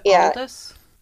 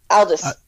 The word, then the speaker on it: Aldous: Aldous Huxley. He wrote Brave New World. And he Really? – Is it Aldous? – Aldous, sorry.